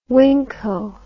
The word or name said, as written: Winco